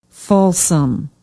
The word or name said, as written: Folsom